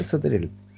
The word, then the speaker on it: Sauter: ¿Sautrel? Eh, se, lee Sotrel. Sotrel. Ah, perfecto. Sotrel. sí.